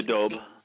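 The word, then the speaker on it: daube: Next on the list is the, you'll pronounce it, dobe? – Oh, the dobe.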